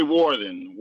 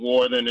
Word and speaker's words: Wharton: Yeah, this is Ray Wharton. Last name is Wharton. It's German, unfortunately. It's Ray Wharton. People say it wrong all the time.